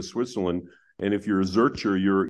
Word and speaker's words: Zurcher: Am I pronouncing that right? – Yeah. Well, Zurcher. Zurcher is actually a name of a citizen of Zurich, actually.